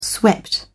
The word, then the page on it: swept: British English